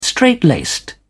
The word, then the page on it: strait-laced: British English